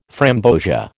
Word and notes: framboesia: American English

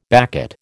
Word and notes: baccate: American English

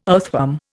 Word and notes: earthworm: Indian

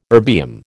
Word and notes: erbium: American English

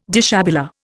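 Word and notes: déshabillé: Indian

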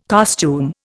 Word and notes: costume: Indian

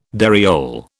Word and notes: dariole: British English

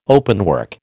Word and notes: openwork: American English

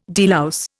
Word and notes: delouse: Indian

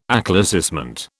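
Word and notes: éclaircissement: British English

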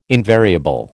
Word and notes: invariable: American English

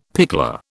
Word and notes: pickler: British English

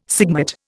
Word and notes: sigmate: Indian

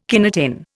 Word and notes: kinetin: Indian

